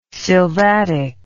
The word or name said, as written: sylvatic